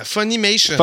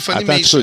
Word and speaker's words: Funimation: Funimation. Oui, Funimation.